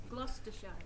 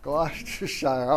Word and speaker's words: Gloucestershire: It is Gloucestershire. – Gloucestershire.